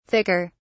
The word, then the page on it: thicker: British English